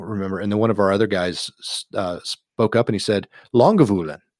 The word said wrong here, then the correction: Lagavulin: How do you say it? I can't say the word. – Lagavulin. – Lagavulin. Lagavulin.